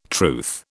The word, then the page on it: truth: British English